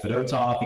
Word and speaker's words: Fedotov: Same thing with like a guy like Fedotov, you gotta add the accent on the second syllable, not the first syllable, so we're going with that until he tells us otherwise.